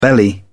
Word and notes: belly: British English

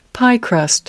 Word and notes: piecrust: British English

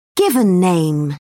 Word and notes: given name: British English

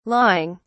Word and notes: lawing: British English

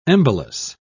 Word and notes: embolus: British English

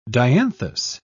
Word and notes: dianthus: British English

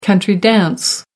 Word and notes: country-dance: American English

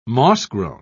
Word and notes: mossgrown: British English

